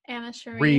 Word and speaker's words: Anna Cherie: Why couldn't you have gave me like Anna Cherry or Anna Cherie. – Rihanna? Cherie, is that how you say her name? – Yeah, I think so.